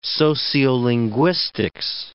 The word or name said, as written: sociolinguistics